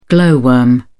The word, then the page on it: glow worm: British English